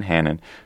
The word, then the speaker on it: Hanen: Judge, how do you say his last name? Hanen, who's a quite conservative judge,